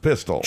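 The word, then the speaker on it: Pistole: Now it's spelled with an E on the end, but it's pronounced Pistol.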